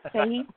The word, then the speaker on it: Fahey: And Shane, how do you pronounce your last name? – Well, Fahey would be... – Okay. – The way most Americans say it. – Fahey? – Yeah, Okay.